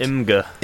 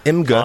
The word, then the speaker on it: Imgur: Okay, this guy, there's this website called Imgur. Is that how it's pronounced? – Imgur. – Imgur. – I-M-G-R.